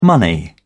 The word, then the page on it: money: British English